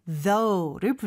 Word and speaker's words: though: Asking, A-S-K-I-N-G. – 그쵸. Thanks for asking까지만 얘기하면 물어봐줘서 감사합니다인데 그래도라는 느낌을 살리고 싶으면 맨 뒤에 though를 붙여주면 돼요. though는 스펠링이 조금 독특해요. – Right, it's T-H-O-U-G-H. – 마지막 G-H는 발음을 여기서 하지 않죠, 그쵸? though. – O-U-G-H, you just have to memorize the pronunciation because there are so many different words with different pronunciations.